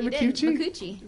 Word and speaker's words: Micucci: Also known as Ricky Lindholm and Kate McCucci. – Hi. – Did I pronounce that right, McCucci? – McCucci.